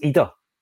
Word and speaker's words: Idah: Apparently, it's Idah, so you've got to call him Adam Idah.